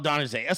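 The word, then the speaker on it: Adesanya: That's is that how you say his name? Adonijé. He's got a weird fucking name. Dude's a badass, though. Dude's a total badass, and he was excited to meet Trump. Everybody wanted to meet Trump. This is what happens. DJT gets down there. Right, so it is champ, Israel Adonijé. So I said it right. I had it right.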